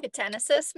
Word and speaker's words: yucatanensis: A group of Taiwanese, Japanese, and Australian researchers discovered the, and I'm going to butcher this, the Bathynomys yucatanensis. – Not that bad. Yucatanensis, maybe? – Yucatanensis. That sounds better.